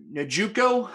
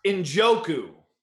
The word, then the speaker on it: Njoku: I think that on DK, you can look at David Njoku. How do we say his name? – Njoku.